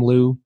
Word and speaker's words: Leu: Well, Tom Liu is my name. L-E-U is the last name. It sounds like I have two first names.